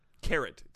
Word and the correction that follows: carreau: It's pronounced carreau. It's a special feat in which the shooter knocks the opponent's bowl out while leaving his bowl very near to the impact. It's pronounced caro.